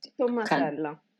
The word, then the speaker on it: Tommasella: Tommasella.